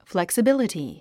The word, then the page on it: flexibility: flèksəbíləti; フレクサビリティ